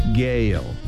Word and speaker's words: Gaal: G-A-A-L Gale. 한 번 더요. Gale. 가알을 Gale 이렇게 발음하시면 됩니다.